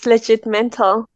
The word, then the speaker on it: Legitmental: Legitmental. Did I pronounce it? – Yes, Legitmental.